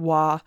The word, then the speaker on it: Waugh: It's W A U G H. – I would, I would think Waugh.